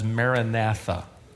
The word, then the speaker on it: Maranatha: You squeeze those words together and you get what we typically pronounce as Maranatha.